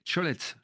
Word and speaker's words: cholets: Vous allez nous faire découvrir les Cholets. Je l'ai bien prononcé, c'est ça ? – Très bien.